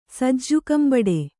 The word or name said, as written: ಸಜ್ಜುಕಂಬಡೆ